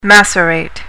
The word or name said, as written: macerate